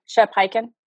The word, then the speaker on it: Hyken: And of course, now I'm probably going to butcher his name, Shep Hyken, right? – Mm-hmm. – Is that how you say his last name? – Yes, Shep.